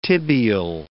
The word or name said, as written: tibial